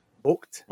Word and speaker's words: Vogt: Adam Vogt, V-O-G-T. Vogt.